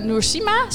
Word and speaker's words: Noursima: En voor het volgende onderwerp zijn aan tafel aangeschoven Noursima, zeg ik dat goed? – Ja.